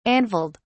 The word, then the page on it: anvilled: British English